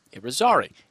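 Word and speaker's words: Yrizarri: His first name is spelled Y-E-Y-S-O-N, Jason. His last name is spelled Y-R-I-Z. A-R-R-I, Y-R-I-Z-A-R-R-I, and that's pronounced Irizarry.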